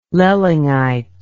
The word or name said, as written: loellingite